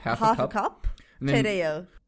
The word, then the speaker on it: Tereyağı: Tereyağı. We don't read it. It's a silent letter, Justin. – Right. – Tereyağı. – But it makes that A a little longer, doesn't yes.